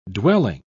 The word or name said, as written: dwelling